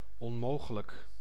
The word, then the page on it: onmogelijk: /ɔnˈmoːɣələk/